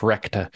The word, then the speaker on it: Recht: And what it's recht, I guess, is how you pronounce it. I don't know how to say German things. Everybody knows that. – Recht. – Yeah, like get wrecked.